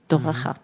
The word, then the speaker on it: Toraja: que se dice Toraja. Uh-huh. Yo no lo pronuncio bien porque todas las palabras que tienen la R y la J me están costando.